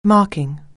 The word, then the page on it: marking: anglais